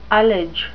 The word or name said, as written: ullage